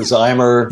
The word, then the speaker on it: Zimmer: Is it Zimmer or Zimmer? How do you say it? – It's whatever you like. – Okay. – I prefer Zimmer.